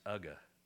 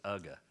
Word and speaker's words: UGA: And the name is spelled UGA for the University of Georgia, but it's pronounced Ugga. And that's the name of the Bulldog, is Ugga.